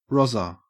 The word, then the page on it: rozzer: Australian English